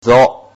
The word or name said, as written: zo